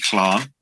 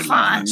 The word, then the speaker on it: Llarn: Okay, so the word is actually Clan, if you want to try and pronounce it in Welsh, but you can say Larn. That's totally fine.